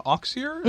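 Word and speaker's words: Auxier: The Night Gardener, Jonathan Oxier, is that how we're pronouncing that? – Yep.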